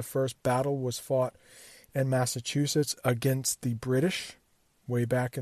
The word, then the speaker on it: Massachusetts: Massachusetts. Think of it like this: Mass, M-A-S-S, just like an A, chew, like you do to your food, C-H-E-W, and then sits, to like sit down, Mass, chew, sits, Massachusetts.